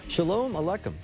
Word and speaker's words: Shalom Aleichem: Shalom Aleichem.